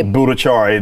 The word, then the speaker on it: Bhattacharya: Bhattacharya, isn't it? – Yes.